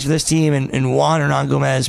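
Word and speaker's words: Hernangómez: Hermanguez. – Hernan Gomez? – Hernan Gomez, yeah. That's how you pronounce his name? – Hernan Gomez, yeah. It's like Hernandez, but instead of the Andes at the end, it's Gomez at the end.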